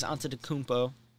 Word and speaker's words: Antetokounmpo: So it's going to be Jason Tatum, Giannis Antetokounmpo.